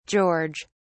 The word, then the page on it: george: British English